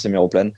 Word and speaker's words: Héméroplane: Héméroplane, tu fais bien de poser la question, tu l'as très bien dit tout du long, il n'y a pas de souci, on le prononce Héméroplane.